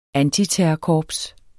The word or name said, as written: ANTITERRORKORPS